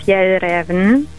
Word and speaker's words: Fjällräven: Fjällräven.